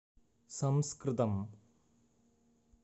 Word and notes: saṃskṛtam: ˈsɐ̃skr̩tɐm

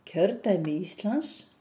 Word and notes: Kjördæmi Íslands: Icelandic

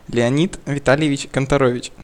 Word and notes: Леони́д Вита́льевич Канторо́вич: Russian

